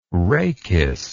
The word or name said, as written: rhachis